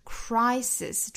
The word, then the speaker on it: crisis: out of every crisis crisis，注意两个 s 都是读清辅音的 s， crisis Crisis，中间的这个字母 I 要读成一个双元音 I 的发音， Crisis Crisis，不要读成 Crisis、啊、后面这个是单元音 A， Crisis Crisis， Come 加 S 读成 Comes Comes，尾部的这个 S 要读成浊辅音 Z，再和后面的 Opportunity 连读， Comes Opportunity Comes Opportunity。